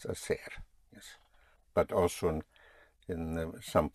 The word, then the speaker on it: hacer: To do or to make is hacer, spelled H-A-C-E-R. Again, the H is not pronounced, so you have hacer, to do.